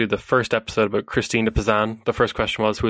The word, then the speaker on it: Christine de Pizan: Christine de Pizan, yes.